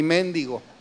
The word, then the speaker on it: méndigo: Una vez yo dije aquí que hay palabras que se escriben igual pero se pronuncian diferente, y les puse un ejemplo, la palabra mendigo y méndigo.